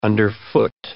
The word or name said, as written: underfoot